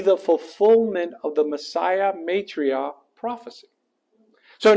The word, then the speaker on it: Maitreya: How do you pronounce that? Maitreya, thank you.